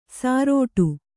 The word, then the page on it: ಸಾರೋಟು: sārōṭu